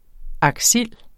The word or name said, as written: aksil